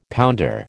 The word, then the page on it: pounder: American English